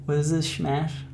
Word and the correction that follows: shamash: Yeah, shamash.